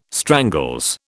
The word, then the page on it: strangles: British English